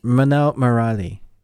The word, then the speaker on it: Minnal Murali: Minnal Murali.